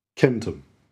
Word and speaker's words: centum: Although we usually read the C-E-N-T Latin syllable as cent in English, like in centurion, centimetre, century, and so on, the original pronunciation was with a hard C, centum.